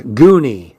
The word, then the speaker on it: Gunny: Gunny pronounced her name so that it sounded like Goony.